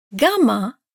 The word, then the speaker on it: gamma: Gamma, gamma, the Greek equivalent of G, has two pronunciations. – If gamma is followed by a front vowel like E or I, it sounds approximately like the Y in year.